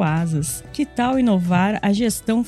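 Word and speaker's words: Asaas: Lembrando que Asas se escreve A-S-A-A-S e conheça a conta digital completa para empresas.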